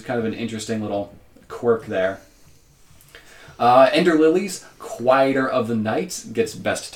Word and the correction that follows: Quietus: Even better, saying it correctly, Quietus.